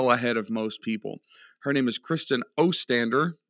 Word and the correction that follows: Ostrander: Did I say that right? Ostander? Yes, Ostrander. Oh man, I messed it up. We'll I don't know. All right. Her name is Kristen Ostrander,